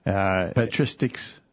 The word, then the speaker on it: patristics: Patristics. – Patristics. Yes, that's right.